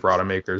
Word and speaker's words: Rodemaker: But Roadmaker, Rodemaker, however you want to say it. Roadmaker sounds way more badass, even if Rodemaker is the correct way to pronounce the name.